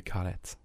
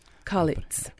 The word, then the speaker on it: Kalettes: Nein, das kommt eigentlich von Kale und wir sagen Kalettes. – Kalettes.